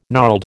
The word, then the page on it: gnarled: American English